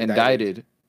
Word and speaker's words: indicted: Indicted. Wait, no? – Yeah, it's indicted. Indicted. – Oh, is it like... – The sea is silent.